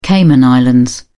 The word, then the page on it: cayman islands: British English